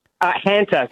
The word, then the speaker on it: Hanta: it's the Hunter virus, am I saying that right? – Hanta. So Hanta. Discovered in the Hantan River in South Korea, so Hanta virus.